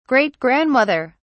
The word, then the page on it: great grandmother: British English